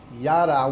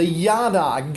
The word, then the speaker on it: yada: As a matter of fact, the word in the scripture that we're going to focus on, the word K-N-E-W, knew, or know, K-N-O-W, is the Hebrew word yada. Yada, Y-A-D-A. I can spell that. Yada. Not yada, but yada.